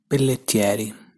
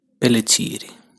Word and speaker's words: Pelletieri: So this is how in standard Italian accent you're supposed to pronounce that surname. Pelletieri, Pelletieri. This is how I would pronounce it with my local accent. Pelletieri, Pelletieri.